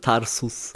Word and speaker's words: Tarsus: Genau, hast du es fast richtig ausgesprochen. Tarsus.